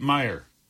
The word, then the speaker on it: Myre: He also killed 16-year-old Tate Meyer. Might be pronounced Mir, but either way. Tate Meyer is 16 years old. M-Y-R-E is the last name spelling.